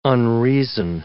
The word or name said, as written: unreason